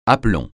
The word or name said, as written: aplomb